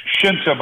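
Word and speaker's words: Scinta: Well, it's the name is Shinta, but it's S-C-I-N-T-A, It looks like Cinta, but it's Shinta.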